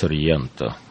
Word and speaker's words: Сорренто: А, Володя, хорошо вы произносите Сорренто.